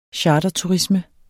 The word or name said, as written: charterturisme